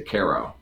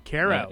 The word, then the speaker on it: Cairo: And so, you do not say Cairo, you say Cairo. – Cairo, yes, instead of Cairo.